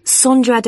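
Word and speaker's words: Sondra: Sandra Da Costa. – Is that Sandra? – No, it's spelt with an O. – So that's S-O-N-D-R-A. – Mm-hmm. – And can you spell your surname, please? – It's D-A-C-O-S-T-A.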